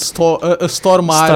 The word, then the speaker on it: Stormare: Sim, é a verdadeira pronunciação de Stormare. – Stormare, em sueco.